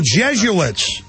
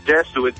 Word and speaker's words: Jesuits: No, it's spelled J-E-S-U-I-T. It's like, it's some type of like from the Vatican, Yo, Jesuits. – Jesuits, yes.